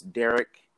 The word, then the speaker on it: Derick: So you can find me on my personal accounts, Derek.